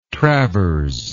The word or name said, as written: travers